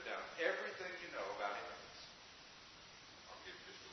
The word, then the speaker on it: Emmaus: Emmaus, right? And you can spell it even. E-M-M-A-U-S.